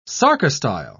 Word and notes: sarcostyle: British English

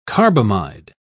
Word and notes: carbamide: British English

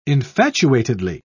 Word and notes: infatuatedly: British English

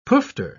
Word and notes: poofter: British English

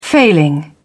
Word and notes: failing: British English

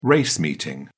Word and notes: race meeting: British English

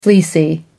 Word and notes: fleecy: American English

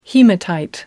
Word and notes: hematite: American English

